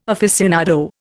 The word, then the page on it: aficionado: Indian